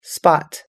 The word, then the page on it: spot: us-english